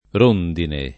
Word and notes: rondine: lingua italiana

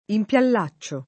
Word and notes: impiallacciare: lingua italiana

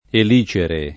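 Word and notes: elicere: italiano

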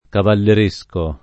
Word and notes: cavalleresco: lingua italiana

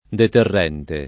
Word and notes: deterrente: lingua italiana

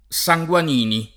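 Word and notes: Sanguanini: italiano

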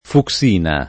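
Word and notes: fucsina: italiano

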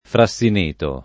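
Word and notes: frassineto: italiano

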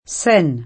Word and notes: Senna: S$ nna

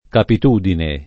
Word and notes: capitudine: italiana

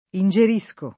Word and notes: ingerire: lingua italiana

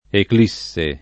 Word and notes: eclissi: ekl &SS i

